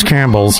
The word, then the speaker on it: Campbell's: But then she said, her mom said it's even better than Campbell soup. And I'm like... I just let it go because I'm like, honey, that's Campbell's. This is the only place in the world that screws up the pronunciation of it.